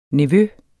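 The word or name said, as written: NEVØER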